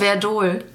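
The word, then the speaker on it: Werdohl: Wie spricht man das richtig aus? Verdol. – Verdol? – Ja. Oh Gott, dann habe ich dein L vergessen.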